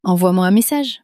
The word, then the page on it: envoie-moi un message: French